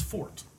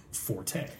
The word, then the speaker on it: forte: And so, in theory, you're pronouncing it wrong, but the accepted pronunciation, the modern pronunciation, is right. – Forte. Everybody says it. It's in the dictionary.